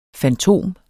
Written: fantom

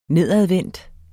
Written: nedadvendt

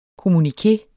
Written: kommuniké

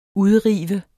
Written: udrive

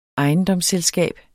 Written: ejendomsselskab

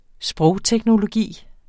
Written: SPROGTEKNOLOGI

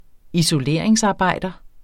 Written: isoleringsarbejder

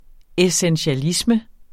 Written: essentialisme

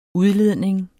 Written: udledning

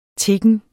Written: tiggen

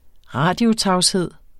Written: radiotavshed

